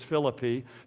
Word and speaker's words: Philippi: Now, that's the way it's actually pronounced. I'll say Philippi because that's what people are used to hearing, but actually it's Philippi. If you say Philippi, people don't know what you're talking about, so we'll keep saying Philippi.